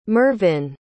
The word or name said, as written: mervyn